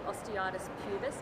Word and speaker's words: osteitis pubis: You've had osteitis pubis. Did I say that right? – Yes, you did.